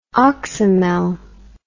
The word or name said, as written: oxymel